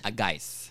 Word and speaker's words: Guice: Ted Juice. – Geis. – Geis. – Yeah. – It's spelled G-U-I-C-E, but it's pronounced Geis.